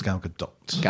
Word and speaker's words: Gal Gadot: I think it is actually. I think we've come to the conclusion it's Gal Gadot.